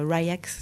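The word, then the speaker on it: RY X: J'écoute aussi, je vais le prononcer pour que tout le monde comprenne, c'est Ryx, R-Y-X, c'est Ryax, c'est un Australien qui travaille avec des orchestres, etc.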